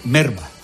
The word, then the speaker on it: melva: O sea, en realidad se escribe melva, pero decir se dice merva.